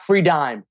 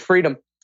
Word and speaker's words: Freedym: That's not Free Dime for all the wise ass at home. No, it's Freedom. – And for the people who are not seeing this on video, it's spelled Freedym. So that's why I said Freedom. – R-E-E-D.